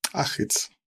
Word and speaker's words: Achitz: Did I say it right? Anchitz? – Achitz. – Achitz.